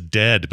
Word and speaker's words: Dehd: His name is Dead, but it's spelled D-E-H-D.